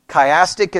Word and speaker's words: chiastic: Chiastic is spelled C-H-I-A-S-T-I-C.